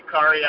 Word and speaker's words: Khary: Kari Allen. – Kari? How you spell that, brother? – K-H-A-R-Y.